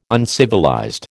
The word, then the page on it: uncivilized: American English